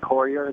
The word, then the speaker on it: Courier: Billy Carruthers, and I want to pronounce your name right, Corher, Billy Corher. Did I get that right, Billy? – Actually, it's just Courier. The H is silent.